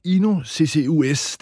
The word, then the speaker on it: INO CCUS: Carina Søgaard, du er direktør for INO CCUS. Det er lidt svært at udtale, men hvad er I sat i verden for at løse? – Ja, du udtaler det helt rigtigt, vil jeg sige.